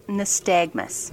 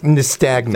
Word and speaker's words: nystagmus: But it's actually pronounced nystagmus. – Nystagmus. – Thank you. – Knew I was mispronouncing it.